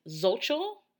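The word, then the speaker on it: Xochitl: And also, Miss Lan's real name is Zolchel. I hope I'm saying that right. I have a friend with that name, and that's how we say it. That's how she pronounces it. But it's X-O-T-C-H-L.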